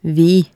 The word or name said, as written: vi